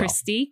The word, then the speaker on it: Kristy: Kristy, K-R-I-S-T-Y, Marmorato, M-A-R-M-O-R.